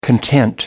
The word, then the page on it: content: American English